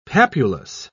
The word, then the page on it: papulous: British English